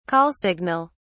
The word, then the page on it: call signal: British English